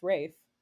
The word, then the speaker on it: Ralph: His full name is Ralph, spelled Ralph, pronounced Rafe.